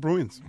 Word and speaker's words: Bruins: ¿Cómo se dicen Bruins? A ver, de Boston. No, los Bruins son Bruins.